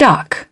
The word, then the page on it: duck: British English